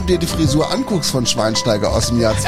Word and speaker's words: Schweinsteiger: Shiwainushi Taigari. Schweinsteiger.